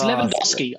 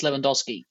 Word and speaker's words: Lewandowski: It's Lewandowski. I know it's Lewandowski because I also used to speak to someone who was Polish and also into football, and he told me it's Lewandowski.